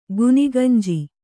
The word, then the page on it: ಗುನಿಗಂಜಿ: guniganji